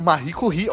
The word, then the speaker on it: Marie Curie: Mary Curie, não sei se eu pronuncio. Mary Curie, Marie Curie.